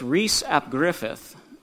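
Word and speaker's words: Rhys ap Gruffydd: And the app is like the word of. So he's Rhys of, and believe it or not, that final word is, when it's anglicized, is pronounced Griffith, like Andy Griffith. So it doesn't quite look like Griffith to us, but so it's Rhys ap Griffith, and that's an approximation of what his name was.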